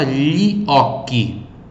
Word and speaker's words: gli occhi: Então, GLI, som de Li, e CHI, som de Qi, ok? Repito em voz alta, Li Occhi.